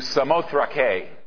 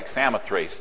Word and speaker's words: Samothrake: So we went across, made the voyage to Samothrake, is the way it would be pronounced in Greek. Looks like Samothrace, doesn't it, kids? Samothrace, Samothrake. And on to Neapolis, that's New City, and then on to Philippi.